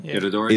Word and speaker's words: Itadori: Like Itadori, am I saying this right? – Itadori is way more badass in the manga than he is in the fucking anime.